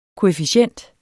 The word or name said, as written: koefficient